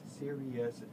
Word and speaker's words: Sirius: So Sirius, pronounced Sirius, is the brightest star in the constellation Canis Major.